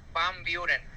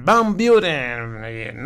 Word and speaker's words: Van Buren: La calle esa que dice se llama Van Buren, pero así como tú la dices, como se escribe, pues, pero se pronuncia Van Buren. – Van Buren.